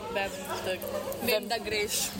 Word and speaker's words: Vem da Greš: As someone who doesn't speak Slovenian, how do you say Vem da Greš? – That was very well pronounced.